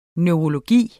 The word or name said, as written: neurologi